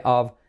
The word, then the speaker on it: of: Help of, que suena como una V, of.